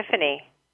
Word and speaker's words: epiphany: It's the epiphany? Word epiphany. Yes, okay, epiphany, like a light bulb going off on top of your head.